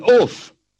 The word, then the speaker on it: ouphe: An oof, O-U-P-H-E, is an elf, gnome, or goblin. Lunting, L-U-N-T-I-N-G, means walking and smoking a pipe at the same time.